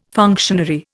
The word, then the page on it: functionary: Indian